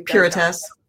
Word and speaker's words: Pirotess: Now you Puritas. – Yeah, I knew you knew it. Yeah. How do you spell that? – P-I-R-O-T-E-S-S. Kind of like Pirouette.